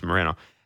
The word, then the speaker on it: Moreno: If Kikuchi has another wobble with Moreno, and that, by the way, is how he wants to pronounce his name is Moreno.